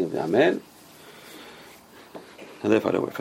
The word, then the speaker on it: amen: Amen, it's a long syllable. Amen. Right? So the first syllable is with a Kamatz. Ah. So Amen. Amen.